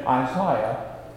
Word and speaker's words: Isaiah: By Jesus taking and opening the scroll of Isaiah, or as the deacon said this morning, the British pronunciation, Isaiah.